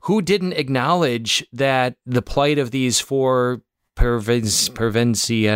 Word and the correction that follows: Pevensey: I can't pronounce her last name. Per, there we go, Pevensey children.